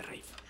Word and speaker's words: Ralph: Rafe se escribe R-A-L-P-H, ¿no? – Por supuesto. Padre español, madre inglesa. Así que no es Rafe. Se pronuncia Rafe, aunque se escribe R-A-L-P-H, claro.